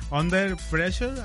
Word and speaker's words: Under Pressure: Fíjate que yo hubo un tiempo que escuché muchísimo, muchísimo, la de, no sé si lo voy a pronunciar bien, Under Pressure, así se pronuncia.